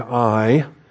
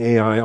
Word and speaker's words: Ai: All right, and so moving beyond the initial battles of Jericho and Ai, I've been mispronouncing Ai all these years. We're going to remedy that today by insisting upon the I pronunciation.